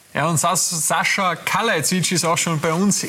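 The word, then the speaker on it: Kalajdžić: Sascha Kalajdzic.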